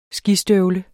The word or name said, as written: skistøvler